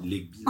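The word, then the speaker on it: Lebghil: William Legbi euh, Legby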